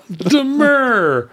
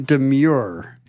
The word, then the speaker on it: demur: Demur. – So to anyone who has been saying demure incorrectly in the Whoa. Future, we need to set the record straight. Please immediately adopt the correct pronunciation that Reva endorses. – So thank you, Reva. I don't know if I will be able to overwrite my wrong pronunciation because I have a list of, as long as my arm. But make this demur. One a priority. – Demur, because I use that a lot.